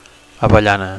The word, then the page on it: avellana: Catalan